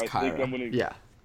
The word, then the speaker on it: Kira: So I'm deciding between Kira and Cole, or Kyra and Cole. I don't know which way to pronounce it. And I believe it's Kyra.